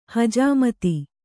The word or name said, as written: ಹಜಾಮತ್